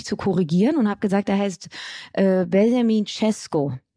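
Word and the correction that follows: Šeško: Auch das ist nicht korrekt. – So? Es hat sich nämlich Annalena bei mir gemeldet und ähm, sie hat gesagt, dass der Name komplett falsch ausgesprochen wurde. Sie ist halb Slowenin und sie würde mir jetzt gerne mal eine Sprachnachricht schicken mit der richtigen Aussprache. Dann sind wir auch alle schlauer. So, aufgepasst? Benjamin Shisho.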